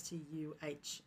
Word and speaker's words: Stuhmcke: So my surname, as you might know, is Stuhmcke, and it's brought nothing but problems into my entire life. The problem with my name is it has four consonants together, H-M-C-K.